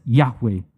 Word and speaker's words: Yahweh: And this has been condensed into 4 consonants called the tetragrammaton, YHWH, and very often is pronounced as Yahweh.